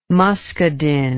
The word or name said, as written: muscadin